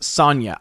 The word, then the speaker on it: Sonja: Your Muslim uncle says, in Swedish, it's pronounced Sonja. I thought it was Sonja, but I wasn't 100% sure.